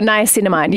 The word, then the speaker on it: niacinamide: I'm going to really butcher this word, but niacinamide. – Oh, niacinamide.